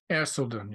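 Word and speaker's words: Erceldoune: Thomas of I think it's Erseldon. Is that how it's pronounced? – Erseldon. Yes. Right.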